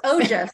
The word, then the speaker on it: Ojas: Today I have the pleasure of interviewing Nano Ujas. Am I saying that correctly? Close, Ojas. Ojas. Ojas.